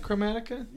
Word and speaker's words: Chromatica: Is that how you say that? Chromatica? – Yes.